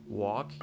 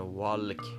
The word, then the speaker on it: walk: این L تلفظ نمیشه تو هیچ کدوم از این کلمات خب پس ما نمیتونیم به صرف این که این حرف در اون کلمه وجود داره اینو تلفظ بکنیم پس واک هیچ وقت نمیتونه والک باشه یا کولد یا شولد یا مثلا تالک